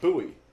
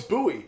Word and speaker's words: Bowie: I yell at people in Maryland all the time because we have a town called Bowie. – Yeah. – But it's spelled like Bowie. Like David Bowie or a fucking Bowie knife. And they're like, no, it's Bowie.